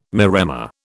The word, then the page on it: maremma: British English